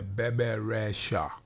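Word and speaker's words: Bebe Rexha: Is that how you say it? Yeah, Bebe Rasha. Bebe, the baby Rasha. I call it baby Rasha, but I think it's Bebe Rasha.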